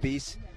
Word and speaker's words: Biese: B-I-E-S-E. And so I'm going with Beast.